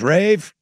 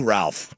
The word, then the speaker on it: Ralph: And he goes, Rafe, if you don't win tonight, we're going to start calling you Ralph. – Love it. You don't deserve the correct pronunciation.